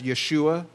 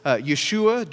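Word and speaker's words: Yeshua (ישוע): I think that our Messianic Jewish brothers and sisters tend to pronounce it as Yeshua, and so that's probably the proper pronunciation. And so it's Yeshua, direct from Hebrew.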